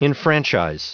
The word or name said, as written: enfranchise